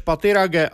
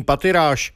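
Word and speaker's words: Pathirage: Vyslovoval své jméno Rumesh Pattirage a vyloučil anglickou výslovnost svého příjmení Pattiráž.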